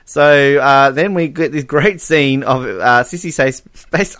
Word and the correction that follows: Spacek: I can't speak. Sissy Spacek. You can't say her name, not in the list. Sissy Spacek. There we go.